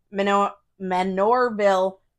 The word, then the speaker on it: Manorville: Manure. – Manorville. Did I say? I didn't, whatever. – No, it sounded like manure. – Oh. – Manureville is how you pronounced it. So just say manure.